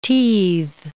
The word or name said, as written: teethe